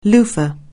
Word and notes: loofa(h): anglais

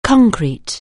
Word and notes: concrete: anglais

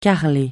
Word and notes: carreler: francais

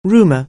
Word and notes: rumour: anglais

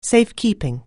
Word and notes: safekeeping: anglais

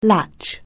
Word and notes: latch: anglais

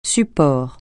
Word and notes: support: francais

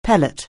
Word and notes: pellet: anglais

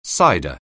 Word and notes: cider: anglais

